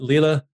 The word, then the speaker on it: Lille: Next week, you're going to be, I'm going to see how much my pronunciation guides help me, but Lille, Groningen, and Eindhoven. – That's correct. Yes.